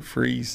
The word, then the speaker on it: Fries: It was founded around uh, 1903 uh, by Colonel Francis Henry Fries and it's spelled F R I E S. And we call it freeze in the winter and fries in the summer.